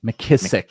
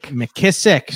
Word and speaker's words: McKissick: J.D. McKissick just – McKissick. – McKissick. Say it right.